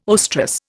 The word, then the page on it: oestrus: Indian